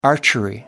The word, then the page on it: archery: American English